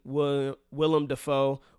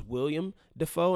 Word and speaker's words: Willem Dafoe: I thought it was William Dafoe, and I'm sure a lot of people listening also thought it was William Dafoe, but it is Willem Dafoe.